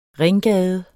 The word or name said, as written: ringgade